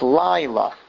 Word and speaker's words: Laila: It's Laila. Because it's emphasized on the second to last syllable, which it's only a two-syllable word, so it's the first syllable.